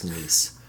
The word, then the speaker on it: Nice: I believe it's pronounced Nice.